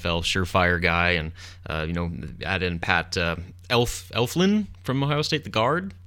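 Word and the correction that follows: Elflein: Eflane, I believe it's pronounced.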